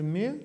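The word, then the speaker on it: me: Пишется «мэ», читается «мэ».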